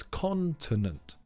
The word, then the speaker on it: continent: The stress is on the first syllable, so it's continent.